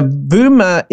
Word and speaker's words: Vooma: Now, Vuma is KCB's fintech startup. They spell it V-O-O-M-A, but it's very similar to the word Vuma in Swahili, which literally means buzz, you know, to create a buzz.